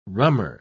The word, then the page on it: rummer: British English